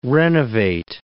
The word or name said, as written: renovate